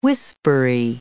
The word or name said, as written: whispery